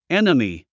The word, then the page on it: enemy: énəmi; エナミィ